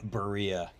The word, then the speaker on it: Berea: And then like going, I used to go to Cleveland, go to the Brea Triangle, if that's the right, how do you say Brea? – Oh, Berea. Yeah.